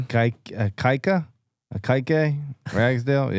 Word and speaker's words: Ikaika Ragsdale: Yeah, last year, Akike Ragsdale. I'm probably butchering that name. – Akike Ragsdale. Yeah.